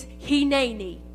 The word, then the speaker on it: hineni: It's hineni. It's very easy to say. Three syllables. Hineni.